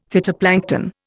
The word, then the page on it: phytoplankton: Indian